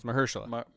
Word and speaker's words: Mahershala: It's Mahershala.